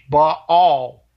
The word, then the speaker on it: Baal: him Baal, but it's not Baal, it's Baal. – Right. – There's two A's in there, and that's how it's pronounced, Baal.